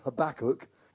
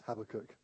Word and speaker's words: Habakkuk: And uh, he talked about the book of Habakkuk except he pronounced it Habakkuk because he was American, and uh, half of his message was trying to get the pronunciation right. But I know it's Habakkuk, not Habakkuk, apparently.